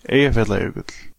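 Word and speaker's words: Eyjafjallajökull: Wir hätten diese Sendung eigentlich gern wollen, den digitalen Vulkanausbruch nennen, aber niemand von uns kann Eia Fiala Jökull richtig aussprechen. – Eia Fiala Jökull. – Ja, also er kann das.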